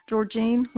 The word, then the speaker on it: Georgine: Georgine Lombardo. – Spell it for me, please. – G-E-O-R-G-I-N-E Lombardo. L-O-M-B-A-R-D-O.